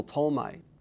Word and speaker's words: Ptolemy: You have the Greco-Egyptian Empire, controlled by first originally General Ptolemy, P-T-O-L-E-M-Y, a silent P, the Ptolemaean Empire, based in Alexandria, what we call the Greco-Egyptian Empire.